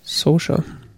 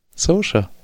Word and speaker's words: Saoirse: Saoirse. Saoirse.